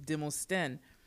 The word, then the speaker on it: Demosthene: And it just kept going to the next level, because my surname is Demosthene. But the H-E-N-E, they pronounce Henne, which means king in Akan.